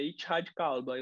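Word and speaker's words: Ittihad Kalba: Me corrija, por favor, se minha pronúncia em árabe não estiver boa, tá? Mas é, é, uma... É. É, é isso, mais ou menos. É isso mesmo, é Itihad Kalba.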